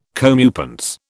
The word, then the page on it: comeuppance: British English